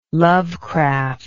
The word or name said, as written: Lovecraft